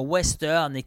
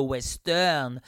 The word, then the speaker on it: Ouesteurne: l'émission Western qui s'écrit euh, comme ceci O-U-E-S-T-E-U-R-N-E. -E. s t e u r n e o u e s t e u r n e On l'écrit comme ça, Western.